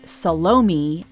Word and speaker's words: Salome: But to call Salome, or Salome as it's pronounced in the movie, a satire is probably to give it too much credit.